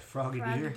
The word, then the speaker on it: Frogadier: Frogadier.